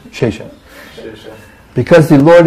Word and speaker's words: Shesha: Shesha. – What did I say? Oh, Shesha. I thought you were saying I mispronounced it. I thought I said Shisha or something.